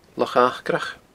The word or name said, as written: Loch Acrach